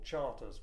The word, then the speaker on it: Chartres: The official British name was Fort Cavendish, but it was actually usually known by the French name Fort Chartres, or Fort Charters, which is how the British pronounced it.